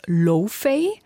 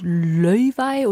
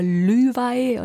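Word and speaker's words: Laufey: Also zuerst dachte ich, sie heißt Lofey, weil man es so vielleicht englisch aussprechen würde. Aber ich habe mir sagen lassen, es wird Löwei oder Lüwei. – Löwei. – Löwei, also genau.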